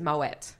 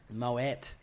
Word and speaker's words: Moët: correct pronunciation is Moët. – Moët. – What? Even though that sounds dodgy, I'm quite sure that I have Googled this many times. And Moët is the correct.